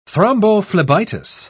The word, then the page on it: thrombophlebitis: British English